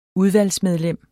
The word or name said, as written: udvalgsmedlem